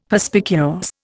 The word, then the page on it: Perspicuous: Indian